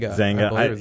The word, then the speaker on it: Xanga: Is that how you pronounce it? – Zanga.